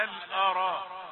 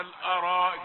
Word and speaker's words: الأرائك: طيب وجزاهم بما صبروا جنة وحريرا متكئين فيها على الأرائك لا تقل على الأراء على الأرائك الهمزة ليست مفخمة على الأ على الأرائك.